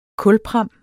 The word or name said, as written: kulpram